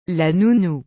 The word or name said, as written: la nounou